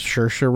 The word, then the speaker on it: Saoirse: Ship and a Bottle from The Lovely Bones with Saoirse Ronan. That's how you say it, folks. Saoirse Ronan.